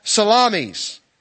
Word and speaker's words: Salamis: And then they arrive in this city called Salamis. At least that's how it's pronounced on YouTube.